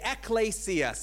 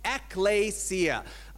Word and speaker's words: ecclesia: Ecclesia. Some people would say ecclesia, but I talk to actual Greek people who say, no, it's ecclesia. So that is the right way to pronounce it.